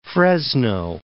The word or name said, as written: Fresno